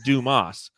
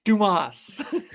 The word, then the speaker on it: Dumas: And somebody said, parents were cruel to name him Richard, with his last name being Dumas, pronounced Dumas. Bro's name is essentially Dick Dumbass. – Yes, Dumas.